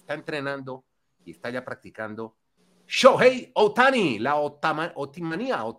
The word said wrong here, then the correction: Otanimanía: ¿se dice así? – Otanimanía.